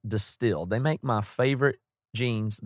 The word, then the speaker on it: DSTLD: It's D-S-T-L-D. You pronounce it Distilled.